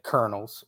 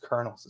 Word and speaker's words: Colonels: Yeah, hopefully if they take care of the Colonels, which is still the most puzzling spelling out there, when it should be Colonels, right? But it's pronounced Colonels. It's just insane. But that's the English language for you.